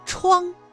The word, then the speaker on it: 怆: 然而这个怆字啊，它还有其他的读音，比如一声 chuang ch u ang 怆，表示悲痛凄怆的意思，因此在这个语境当中，怆是一个多音字，你答对了吗？